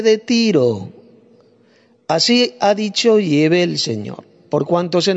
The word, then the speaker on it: Yahvé: Bueno, quiero decir también que he sustituido el nombre Jehová, el nombre falso Jehová, por el nombre Yahvé, ya que esta es la correcta pronunciación del nombre del Dios Padre y de su Hijo Jesucristo, en idioma hebreo arcaico.